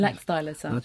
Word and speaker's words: Laxdæla saga: And Caroline Larrington, one of the best known is a saga you've already mentioned, Laxdolage. Is that how you pronounce it? – Laxdæla saga.